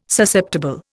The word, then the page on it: Susceptible: Indian